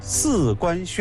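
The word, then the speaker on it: 食官: 叫四官轩。请大家注意，字面上看起来写的是十官这两个字，但是十在这个地方的读音应该是四。为什么呢？因为十也是一个多音字，有几种读法。当我们把它读成第四声四官的时候，它的意思就不是直接的吃，它也不是指食物了，也就是给人家做东西吃，给人与食，这才叫四。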